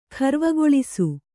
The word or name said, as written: ಖರ್ವಗೊಳಿಸು